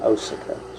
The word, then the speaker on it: Osaka: And he wanted me to accompany him to the acupuncture clinic, which was the Osaka, at the Osaka Medical School, O-S-A-K-A. We were in Osaka, Japan, or Osaka, as it may be pronounced.